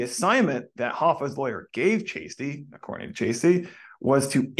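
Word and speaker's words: Cheasty: I know it says Chasty, but that's apparently how you say it. It's Chasty.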